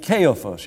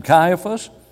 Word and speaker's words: Caiaphas: And the high priest, named Caiaphas, you may pronounce him Caiaphas, being high priest that year.